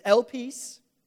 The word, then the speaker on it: elpis: I checked Google to see how to pronounce that word. Now, I don't have a Greek accent. Elpis. I, I don't know. It sounds more Italian. I don't know. It's elpis.